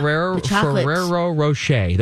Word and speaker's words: Ferrero Rocher: Is it Ferrero Rocher? Am I saying this right? – No, you got it. Yeah. – Ferrero Rocher.